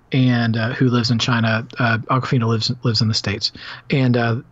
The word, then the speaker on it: Awkwafina: Even though I know she spells it differently, I'm like, surely I'm not pronouncing it the same way as him. But there you go. She's Awkwafina.